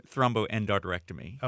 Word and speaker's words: thromboendarterectomy: Well, go ahead and say thromboendarterectomy.